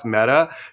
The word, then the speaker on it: Mette: Like M-E-T-T? – Yeah. It's spelled M-E-T-T-E, but it's pronounced Meta.